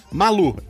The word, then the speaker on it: Malu: Tudo bem contigo, com a senhora Guacha e com a Manu Guaxinim? Malu, M-A-L-U, é que minha pronúncia é ruim.